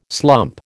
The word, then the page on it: slump: American English